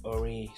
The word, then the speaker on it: Aury: I am your host, Ori Subushimike. Oh, it's spelled A-U-R-Y. Subushimike is spelled S-A-B-U-S-H-I-M-I-K-E.